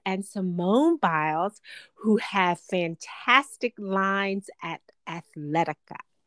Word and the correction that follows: Athleta: Athleta, sorry. Athleta, yes. – And no, it's actually pronounced Athleta.